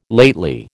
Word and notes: lately: American English

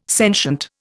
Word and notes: sentient: Indian

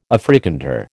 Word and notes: Afrikander: American English

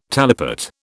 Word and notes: talipot: British English

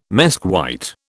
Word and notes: mesquite: British English

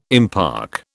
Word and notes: impark: British English

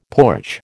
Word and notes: porch: American English

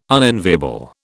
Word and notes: unenviable: British English

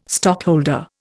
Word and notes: stockholder: Indian

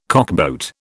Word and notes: cockboat: British English

